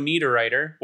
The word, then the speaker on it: Niederreiter: Joe Valeno stomped on the leg of Swiss captain Nino Niederreiter, which I have in my notes how I spelled Niederreiter. N-E-D-E-R-I-T-T-E-R.